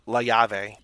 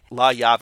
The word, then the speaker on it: La Llave: Probably La Llave. – Cafe La Llave. – La Llave, because I think the double L is like a Y, so like La Llave.